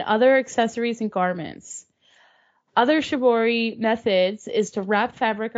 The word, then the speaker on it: Shibori: Shibori is a form of tie-dye which originated in Japan and has been practiced there since the 8th century.